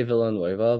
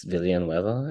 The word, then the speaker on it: Villanueva: Oh, it's I usually just say Villanueva, but in Filipino it's Villanueva, and I believe in Spanish it's Villanueva, so it doesn't really matter.